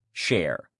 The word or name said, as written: share